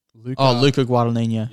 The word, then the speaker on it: Luca Guadagnino: You have to help me pronounce his name. – Luca Guadagnino. – Yeah, that's it.